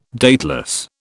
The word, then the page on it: dateless: British English